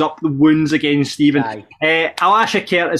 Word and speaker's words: Alashkert: Alaskhert is how we're pronouncing it, isn't it? – Come on.